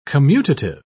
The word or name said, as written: commutative